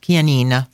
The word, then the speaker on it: Chianina: So I think it's called pronounced Chianina.